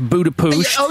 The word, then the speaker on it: Budapest: Your girlfriend Peggy from Budapest. – It's pronounced Budapusht.